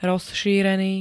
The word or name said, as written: rozšírený